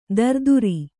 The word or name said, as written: ತರಾತುರಿ